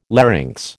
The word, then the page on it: larynx: American English